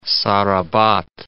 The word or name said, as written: Sarabat